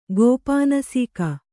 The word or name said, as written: ಗೋಪಾನಸೀಕ